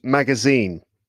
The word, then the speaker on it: magazine: How about the word magazine? – Magazine. It would be at the end.